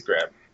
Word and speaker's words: Greb: It's Greb, Miles Greb.